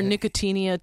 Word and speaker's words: Nicotiana: Nicotiana. Mm-hmm. – Nicotiana. – Or Nicotinia, depends on who you are and how you pronounce it.